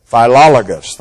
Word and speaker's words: Philologus: One more time. Philologus, thank you.